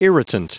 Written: irritant

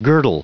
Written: girdle

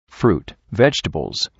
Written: fruit and vegetables

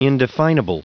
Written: indefinable

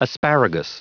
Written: asparagus

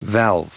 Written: valve

